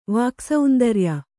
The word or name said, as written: ವಾಕ್ಸೌಂದರ್ಯ